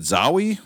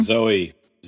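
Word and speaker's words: Zooey: Is it Zowie? How do you say the name? – Zoe Deschanel.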